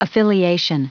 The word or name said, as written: affiliation